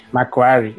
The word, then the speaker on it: McQuarrie: E o nome dele, eu pronunciei bem? – McQuarrie. É isso.